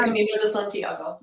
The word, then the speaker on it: Camino de Santiago: Yep, it's the Camino de Santiago.